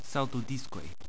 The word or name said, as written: saltodiskoj